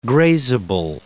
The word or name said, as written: grazable